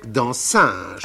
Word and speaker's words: dancing: On prononçait dansinge. – Ah, bah oui, c'est la, la prononciation nogentaise. Naturellement, naturellement, dansinge.